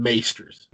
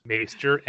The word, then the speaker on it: Maester: I refuse to call them Maesters. – Maester, M-A-E, Maester.